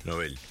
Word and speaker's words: Nobel: Dice que así se pronuncia el apellido de su creador, Alfred Nobel, en su idioma original, el sueco. A pesar de que en español mucha gente pronuncia erróneamente como una palabra grave, incluido yo, la Real Academia recomienda mantener la pronunciación original aguda de la palabra Nobel.